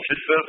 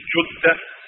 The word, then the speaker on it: Jeddah: But Shaykh Muhammad ibn Salih al-Uthaymeen, rahimahullah ta'ala, who Shaykh Muhammad al-Banna, who lives in Jeddah, commonly called Jeddah, but the correct pronunciation is Jeddah. It's Jeddah. Well, the common people call it Jeddah, but it's Jeddah.